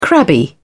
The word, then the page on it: crabby: British English